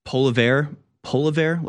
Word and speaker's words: Poilievre: Poilievre, like polar bear?